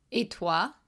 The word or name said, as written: et toi